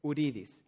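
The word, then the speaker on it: Huridis: It's H-U-R-I-D-I-S, Uridis. But the thing is that in Spanish, the H is silent, so you don't pronounce the H. It's not who, it's U.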